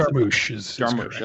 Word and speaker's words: Jarmusch: Jarmusch.